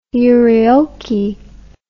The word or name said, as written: euryoky